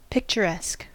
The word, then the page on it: picturesque: American English